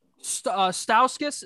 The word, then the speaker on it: Stauskas: Stauskas.